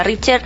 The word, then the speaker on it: Richter: Y no nos vamos muy lejos de México, porque allí un fuerte terremoto de 7,3 grados en la escala Richter. Sí, se dice así.